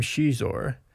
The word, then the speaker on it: Xizor: Lucy Wilson came up with the name Shizor, using the Portuguese pronunciation for X, pronounced sh, in his name,